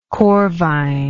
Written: corvine

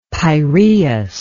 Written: Peiraeus

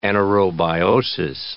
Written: anaerobiosis

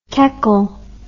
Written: keckle

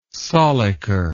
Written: sollicker

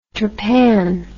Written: trapan

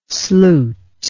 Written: sluit

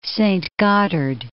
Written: Saint Gotthard

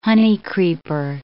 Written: honeycreeper

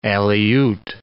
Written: Aleut

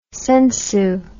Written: sensu

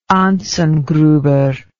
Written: Anzengruber

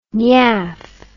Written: nyaff

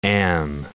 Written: am

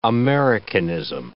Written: Americanism

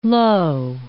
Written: Lowe

